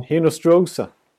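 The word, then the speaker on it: Hinostroza: Nu är det Hartman och Hinostra, eller hur, hur Hinos, man han? Hinostrosa. Hinostrosa. Uttalsexperten.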